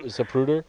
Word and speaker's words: Zapruder: There was the Zapruder film. Is that the pronunciation? – Zapruder.